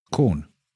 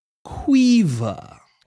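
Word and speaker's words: Caoimhe: Quiver. Yep. That's how it's pronounced. That's how it's spelt. C-A-O-I-M-H-E. E is pronounced quiver if you're Irish.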